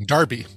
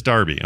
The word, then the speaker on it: Derby: Derby? Mm-hmm. – Darby. – Darby. – Even though it's spelled Derby, but pronounced Darby. – It is Darby.